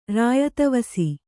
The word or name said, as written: ರಾಯದವಸಿ